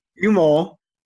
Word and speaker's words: humo: The name comes from the Spanish word humo. Did I say that right? – Yeah.